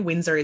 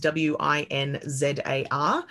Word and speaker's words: Winzar: Winzar is W I N Z A R.